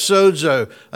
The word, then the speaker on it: sozo: literally the Greek word is the word sozo. S-O-Z-O is the English, I guess, transliteration of that. What's the English? Anyway, sozo is how you pronounce the Greek word literally.